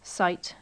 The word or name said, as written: site